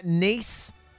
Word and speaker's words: Nice: And we are doing the honeymoon straight after the wedding thing through Glasgow, London, Amsterdam, Paris, Barcelona, Nice, Nice? – Yeah, that's how it's said.